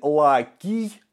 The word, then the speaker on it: Лаций: Ну, а место поселения латинов – это область Лаций или Лакий, как правильно говорить на латыни. Они звука «ц» не знали вообще.